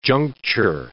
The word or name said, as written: juncture